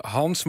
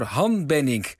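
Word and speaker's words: Han Bennink: Ja, luisteraars Willem, Hetty, Nelleke en Sam kennen hem beter dan wij, want de Oeuvreprijs is niet voor Hans, maar voor Han Benink.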